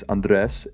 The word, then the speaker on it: Andres: It's Andres, A-N-D-R-E-S, Zanelli, just how it is on the podcast.